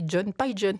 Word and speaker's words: Pigeon: ça s'écrit. Mais ça se dit John Pigeon, il est américain.